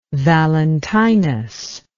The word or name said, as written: Valentinus